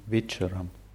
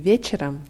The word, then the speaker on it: вечером: Der Unterschied ist, das Wort вечером wird am Anfang deutlich mit W ausgesprochen, weil nach dem W im Russischen ein, Loka, äh, ein Vokal folgt.